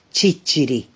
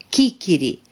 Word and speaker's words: ciciri: Localnicii palermitani i-au ucis pe străzi pe toți acei francezi care nu puteau pronunța cuvântul sicilian ciciri, adică năut, spunând în schimb chichiri sau sisiri.